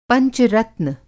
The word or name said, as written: पंच-रत्न